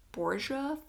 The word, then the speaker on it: Borgia: So she has a series about Rome and about the Borgia family. Is that how you say That is how you say it.